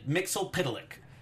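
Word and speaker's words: Mxyzptlk: I loved the pronunciation. I felt like it was the clearest. Because I've said Mitzel-Plick for most of my life. Mixel-Pidalic.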